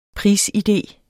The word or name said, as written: prisidé